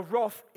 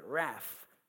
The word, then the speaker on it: wrath: Now, wrath is a great word, okay? Americans say wrath, but wrath, David Lee Roth, that's how you remember it, for anyone over 50.